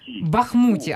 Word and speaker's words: Бахмуті: А до вас питання, а чи купують в Бахмуті, чи в Бахмуті, перепрошуємо, ніяк не запам'ятаємо правильний наголос? – Бахмуті. – Ага, значить я спершу правильно сказала.